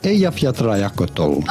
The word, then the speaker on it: Eyjafjallajökull: How do you go about pronouncing this Icelandic word? Eyjafjallajökull. It's actually way easier than you think.